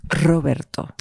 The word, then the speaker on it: Roberto: I was going to say, or as the Spaniards pronounce it. – Roberto. – Don Roberto. We covered this before. Now she's openly mocking me because I can't roll my R's.